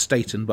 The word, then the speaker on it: Staton: I've got Candy Staton. Oh, she tells me it's pronounced Staten, but I think she's wrong.